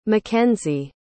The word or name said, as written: mckenzie